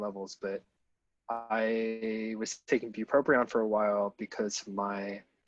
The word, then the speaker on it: bupropion: So this comic is the Escape from Bupropion.